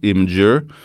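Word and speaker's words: Imgur: En kille som på eh, Imager, eh, alltså R, jag har fått lära mig att det ska uttalas Imager, eh, har lagt ut en, eh, en, en, serie bilder på en jättekul grej han gjorde.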